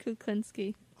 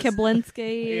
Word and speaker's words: Kuklinski: But two, I've been messing with Chris in the last couple of days of just saying it Kuklinski. – Wrong. Butchering it completely wrong on purpose. – Kiblinski. – Yeah. – Kiblowski.